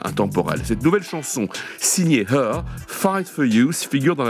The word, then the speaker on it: H.E.R.: Voici une voix que vous avez peut-être déjà eu l'occasion d'entendre, la chanteuse californienne Her, ça s'écrit H-E-R, voilà comme le pronom, un talent, remarquablement précoce, puisqu'elle avait tout juste 20 ans en 2017, quand elle a publié son tout premier album.